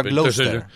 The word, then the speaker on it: Gloucester: Es que se escribió así, Leicester, y se pronuncia Leicester. Como Gloucester, se pronuncia Gloucester.